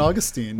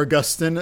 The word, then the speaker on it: Augustine: So our first one is a good old St. Augustine. – Woo! – Or Augustine. I've seen both pronounced.